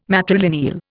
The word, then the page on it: matrilineal: Indian